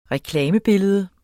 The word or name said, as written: reklamebillede